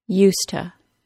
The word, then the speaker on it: used to: In connected speech, however, the word to is reduced to ta, and we have the complete modification of the phrase used to the common, current, yet informal pronunciation of used to.